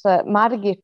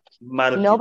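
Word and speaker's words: Margit: It's Madagit, and it's like Madagit. nobody can pronounce it in Swedish either.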